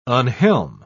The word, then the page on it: unhelm: British English